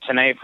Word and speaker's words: tenet: You know, one time I made a very incorrect pronunciation of the word Tenet, T-E-N-E-T. I've been pronouncing it Tenet for years, and Dave pointed out that it's Tenet, you know?